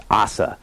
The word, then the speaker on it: Asa: Um, uh, and I learned really quick, uh, so his name is actually pronounced Asa. Um, and I've been saying it wrong,